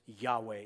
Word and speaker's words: Yahweh: It's coming from the German Yah, It's the J. They use J instead of Y. So people converted that to the English Jehovah, even though it's Yahweh is the correct pronunciation of the name of God.